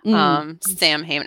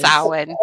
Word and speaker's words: Samhain: I think when I was a teenager, I probably made that mistake based on bad kind of horror movies, Samhain, until I was corrected. So I think it's an honest mistake, but it is Samhain.